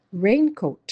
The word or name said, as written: raincoat